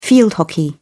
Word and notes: field hockey: British English

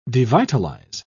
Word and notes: devitalise: British English

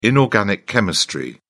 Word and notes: inorganic chemistry: British English

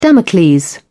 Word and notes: damocles: British English

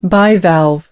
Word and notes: bivalve: American English

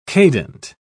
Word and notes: cadent: British English

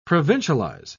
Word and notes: provincialize: British English

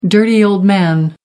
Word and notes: dirty old man: American English

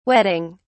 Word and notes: whetting: British English

